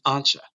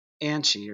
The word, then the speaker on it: Ancha: They and, and remember her name's Ancha. All right. Now I know Dean mispronounces it Anchi or, or Angie or something like that, uh, which is an Anglo Americanized version of it.